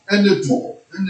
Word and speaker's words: Torah: Same word I just spelled out. – Spell it again. – T-O-R-A-H. – Oh, Torah.